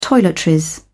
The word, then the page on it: toiletries: British English